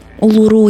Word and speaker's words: Улуру: Я, кстати, не уверена, что я правильно ставлю ударение, но если Улуру я точно нашла, что на последний слог, то про Анангу я заслуживающей доверия информации не нашла, поэтому говорю, как э, говорю.